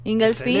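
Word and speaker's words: Ingelsby: escribió Brad Inglesby. Inglesby. – Excelente, excelente pronunciación.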